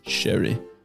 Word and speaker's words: Ceri: How do you say this name? C-E-R-I. How would you say that? – I would say Sherry. – Sherry. As in like Sherry Baby.